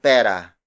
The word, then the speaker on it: pera: For example, if you have zero money in your wallet, you say walang pera.